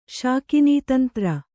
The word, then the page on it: ಶಾಕಿನೀತಂತ್ರ: kannada